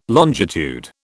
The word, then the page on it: longitude: British English